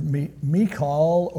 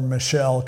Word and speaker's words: Michal: But now we read in verse 20 that Michael, or Michal, or Pastor Chuck used to call her Michelle because it sounds more Western. It's not really the way it's pronounced, but it works.